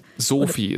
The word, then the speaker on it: Soofi: Sophie, S-O-O-F-I, haben wir gelernt, wird ausgesprochen, ein bisschen wie der Name, aber nicht genau.